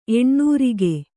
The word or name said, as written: ಎಣ್ಣೋರಿಗೆ